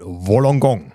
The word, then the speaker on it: Wollongong: Triathlon hat nicht nur auf Hawaii stattgefunden, in anderen Zeitzonen, sondern auch in Wollongong. – Ja. – Habe ich das richtig ausgesprochen? – Also du hast es richtig ausgesprochen, das ist ein sehr sperriger Name.